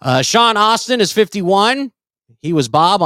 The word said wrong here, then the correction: Astin: Sean Astin. – Yeah. – Sean Astin, not Austin.